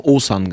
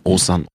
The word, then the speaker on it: Osan: Und da viele meiner Fans ein Problem haben, diesen Namen zu googeln, zu schreiben und irgendwie zu finden, haben wir aus dem Ozan einfach Ozan gemacht. O-S-A-N.